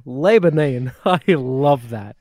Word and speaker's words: Lebanon: Oh, you know, like the country, Lebanon. No. It's goddamn Lebanon.